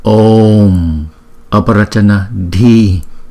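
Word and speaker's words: Om Arapacana Dhīḥ: Cái này là phiên âm theo tiếng Anh, cho nên là không phải đọc là Nade mà là Nadi. Và đây là câu chú tiếng Phạn. Câu tiếng Phạn này được đọc như sau: Om Aparachana Di, Om Aparachana Di, Om Aparachana Di.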